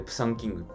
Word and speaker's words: 猫山王: 猫山王でプサンキングって読むらしい